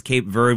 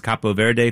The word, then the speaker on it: Cape Verde: And yes, it is pronounced Cape Verde when you say it in English. It's Capo Verde if you say it in Spanish.